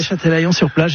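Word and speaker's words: Châtelaillon-Plage: Alors, vous n'avez pas resté très longtemps non plus, vous revenez en France et vous allez, je ne voudrais pas l'écorcher, à Châtelaillon-sur-Plage, c'est comme ça qu'on prononce. – Oui, Châtelaillon-sur-Plage, exactement.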